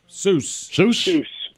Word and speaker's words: SUWS: Do you ever go to some of these, because as I'm looking up the place that you went, S-U-W-S, how do you say it? – Seuss.